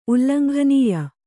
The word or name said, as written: ಉಲ್ಲಂಘನೀಯ